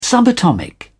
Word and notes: subatomic: British English

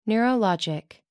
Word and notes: neurologic: American English

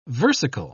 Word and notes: versicle: British English

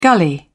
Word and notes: gully: British English

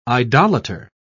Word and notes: idolater: British English

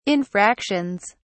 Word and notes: infractions: British English

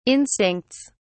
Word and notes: instincts: British English

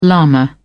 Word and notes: lama: British English

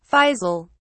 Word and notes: faisal: British English

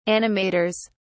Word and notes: animators: British English

